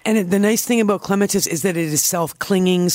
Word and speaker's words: clematis: It kind of depends what, first, number one, how patient you are as a gardener, because clematis comes to mind, properly pronounced clematis.